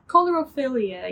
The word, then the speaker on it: coulrophilia: Coulrophilia? – Coulrophilia, yes.